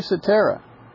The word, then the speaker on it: Cetera: I'm correcting the pronunciation of Peter's name now, which I pronounced incorrectly previously. So Peter Cetera singing "If You Leave Me Now," 1976.